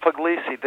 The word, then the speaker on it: Puglisi: You're pronouncing your name wrong. – Pugliesi. – I know, but I'm American now, so I pronounce it Puglisi. The G is now always pronounced.